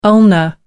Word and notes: ulna: American English

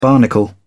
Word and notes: barnacle: British English

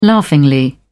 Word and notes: laughingly: British English

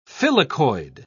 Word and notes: filicoid: British English

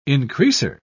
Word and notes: increaser: British English

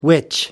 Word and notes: witch: American English

